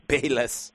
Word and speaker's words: Bayless: Well, how do you pronounce his last name? Ballas? – Bayless. – Bayless. Skip Bayless on ESPN.